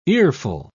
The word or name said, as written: earful